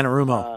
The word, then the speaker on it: Anarumo: Uh, I hope Dorsey is up this week to the challenge of going against Anaramo, Anaramo or however you say his name. – Yeah, Anarumo. Uh, Anarumo, yeah. I mean, because that guy is e- a quality defensive coordinator and right up there with the best in the league.